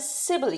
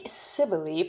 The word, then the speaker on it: Cybele: Cybele cult of ancient Rome and Greece. Now, Cybele is probably not the way the Romans actually pronounced Cybele. They probably pronounced it with a K.